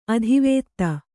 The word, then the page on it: ಅತಿವಾದಿ: kannada